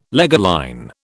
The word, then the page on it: leger line: British English